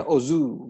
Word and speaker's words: OOO: yaitu Kamen Rider Os atau bacanya Ozu gitu ya Os atau 000 O OOO.